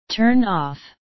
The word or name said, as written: turn off